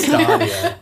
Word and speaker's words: Stadia: It's pronounced stadia.